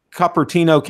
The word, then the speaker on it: Cupertino: Cupertino, California, I believe is how it's pronounced.